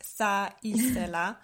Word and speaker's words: θα ήθελα: These two words, θα ήθελα, they were so difficult for me in the beginning to pronounce because in Dutch we don't have the TH sound. And here it's basically double. So it's like θα ήθελα.